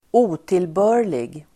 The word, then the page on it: otillbörlig: ²'o:tilbö:r_lig